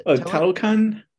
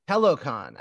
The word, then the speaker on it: Talokan: You have, is it pronounced, what is the, how is the Talokan? – Talokan.